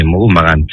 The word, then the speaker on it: Mohun Bagan: Bueno, sí, Mohun Bagan, sí.